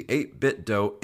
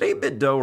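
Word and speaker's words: 8BitDo: Is it 8-bit Doe or 8-bit Do? I never really know how to pronounce it. – I always call it 8-bit Doe. – Yeah, me too. – It sounds cooler to me.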